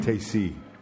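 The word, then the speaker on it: Teijsse: I would pronounce his last name Tacey.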